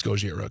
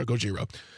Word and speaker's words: Gojira: I always pronounce it incorrectly. I think it's Gojira. Gojira? Gojira.